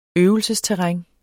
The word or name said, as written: øvelsesterræn